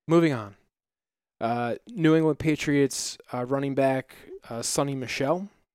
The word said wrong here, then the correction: Sony: Sony. Just exactly how like how it's spelled.